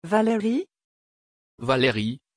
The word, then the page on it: valeri: French